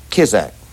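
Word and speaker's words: Kizart: How do you pronounce that guitarist's name? Willie Kizak.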